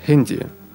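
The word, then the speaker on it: Handy: Телефон, хенди. Читается по-английски.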